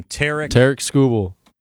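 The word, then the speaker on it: Tarik Skubal: Tarek Skubal.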